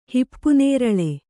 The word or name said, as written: ಹಿಪ್ಪುನೇರಳೆ